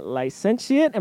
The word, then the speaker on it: licentiate: He has a licentiate. Am I saying that right, Father? – Licentiate. You got licentiate. – It right.